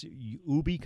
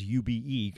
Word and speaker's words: Ube: Jerry, I was asking you before the break with this Ube company. Is that how you say it? I thought it was UBE because I just presumed it was an acronym, but Ube.